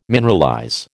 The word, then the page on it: mineralize: American English